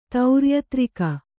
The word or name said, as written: ತೌರ್ಯತ್ರಿಕ